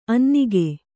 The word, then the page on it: ಅನ್ನೆಗ: annega